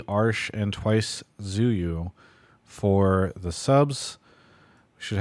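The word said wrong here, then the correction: Tzuyu: Pronounced Chewy after the Twice member. – Is it Chewy? – Yeah. – It's T-Z-U-Y-U.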